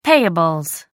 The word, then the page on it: payables: American English